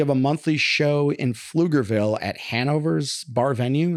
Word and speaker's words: Pflugerville: That's the place that starts with a P. – Yes. – But it is not, you don't pronounce the P. – Yeah. – Pflugerville. – Yeah, I call it Pflugerville.